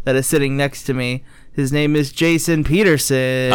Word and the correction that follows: Petterson: Patterson. – Yeah, Patterson. That's how you say it? – Yeah. – Oh, I always thought it was pronounced Peterson.